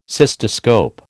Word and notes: cystoscope: American English

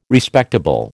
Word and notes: Respectable: American English

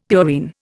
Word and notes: purine: Indian

